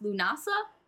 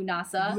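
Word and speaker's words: Lughnasadh: Next is the way it is spelt is not at all how it is pronounced. It is pronounced Lunasa. The spelling is Lughnasad.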